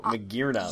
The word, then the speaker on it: Magearna: So Magearna. – Uh, you you pronounced the Japanese name incorrectly. – In a, I, I mean, Magiana? you you turned it into a poke fusion between it and Meganium.